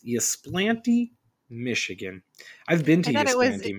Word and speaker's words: Ypsilanti: I think you're right. It's Ypsilanti because it's Ypsilanti.